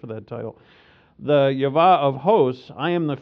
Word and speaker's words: Yahweh: I prefer that pronunciation, Yahweh.